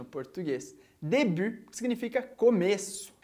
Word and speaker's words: début: Aqui em début, a gente tem o T, que não se pronuncia no final, e a gente também tem o som do U, aquele som do biquinho que a gente viu na primeira aula do mini-cours.